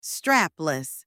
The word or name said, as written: strapless